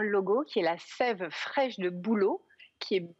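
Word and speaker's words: Sæve: Donc, effectivement, ça se prononce bien Sève. C'est un diphtongue, le E dans le A, et c'est parce que c'est un petit jeu de mots entre la sève qui va sauver votre peau.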